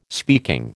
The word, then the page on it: speaking: American English